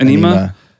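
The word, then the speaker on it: Ænima: How do you pronounce Anima? – It? Anima. – Anima.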